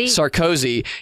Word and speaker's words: Sarkozy: Sarkozy.